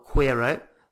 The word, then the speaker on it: quiero: Quiero. Not quiero. So it's a Q-U is a K sound.